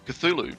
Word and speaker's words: Cthulhu: How do you pronounce it then? Cthulhu. Ah, well done.